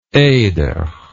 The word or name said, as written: Eder